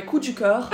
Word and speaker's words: coup de coeur: I'm going to say it like a French person. My coup de coeur is My Family and Other Animals by Gerald Darrow.